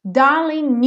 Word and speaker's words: darling: Darling you, aqui a mesma coisa aconteceu no darling, como o G não é pronunciado,